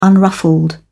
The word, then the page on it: unruffled: British English